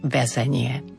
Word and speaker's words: väzenie: Potom máme prehlasované A v slove väzenie. Dnes nie sme viazaní slovenskou výslovnosťou, aby sme toto prehlasované E vyslovovali, ale pokiaľ ste staršej generácie a robilo by vám to problém, pokojne môžete vysloviť väzenie.